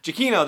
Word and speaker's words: Giacchino: Oh gosh, I know I've messed up the pronunciation of Michael's name. Sorry about that. Giacchino? Giacchino, that's what it's supposed to be.